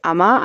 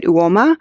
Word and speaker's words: Uoma: And then I really like this brand called, it's pronounced Uma on the website, but then I've heard beauty bloggers say it Uoma.